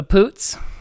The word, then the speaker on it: Poots: Starring uh, Imogen Potts, uh, Poots, um, and Cary Elwes.